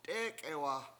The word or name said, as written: t'é:k'ewa